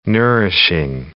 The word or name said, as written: nourishing